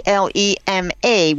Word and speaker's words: Thelema: Well, we got a follow-up email, and what she was asking about is something called Thelma. – Okay. – Thelema was the emphasis on the first syllable.